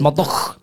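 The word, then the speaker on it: Murdaugh: It's spelt funny, but it's Murdoch with a GH at the end. Right, rather than U C H, so it's Murdoch. It's Murdoch. – A silent H. – A silent G and H.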